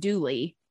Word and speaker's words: Dula: Her lover, whose name is spelled D-U-L-A, like doula, but everyone insists it's pronounced Dooley. So the spelling in the song and kind of historically is Dooley, D-O-O-L-E-Y.